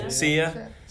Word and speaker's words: Sia: Sia.